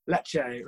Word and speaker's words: Lecce: Lecce, right.